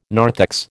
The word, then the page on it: narthex: American English